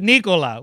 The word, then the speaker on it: Nikola: It's like, okay, but also when Jokic himself is asked how you say the name, he says it Nikola.